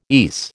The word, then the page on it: ESE: American English